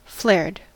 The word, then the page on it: flared: American English